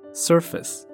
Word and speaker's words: surface: On the surface，表面，这个字很多人会念错，不要看它长得好像有 face，它的读音呢是 surface，surface surface,。没有 face on 表面。